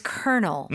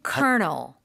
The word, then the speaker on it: colonel: Okay. Colonel. Mm-hmm. Colonel. Now, what you hear right? – is not what you see.